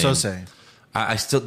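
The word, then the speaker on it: Keyser Söze: Kaiser Soze.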